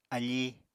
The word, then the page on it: afí: Apatani